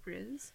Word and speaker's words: Rhys: I don't know how you pronounce his name, but Rhys.